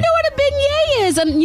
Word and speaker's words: beignets: B-E-I-G-N-E-T-S. – Bignets. – Bignets. I said, it is not Bignets.